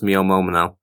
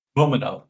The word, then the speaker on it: Momono: So I think her name is pronounced Mio Momono. – Momono, yes, that sounds much better than whatever the hell I said.